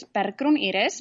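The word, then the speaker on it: Bergrún Iris: Okay, so my name is Bergrun Iris, but you pronounce it really well.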